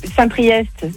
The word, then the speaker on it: Saint-Priest: T'as Saint-Priest, c'est EST, tu le prononces.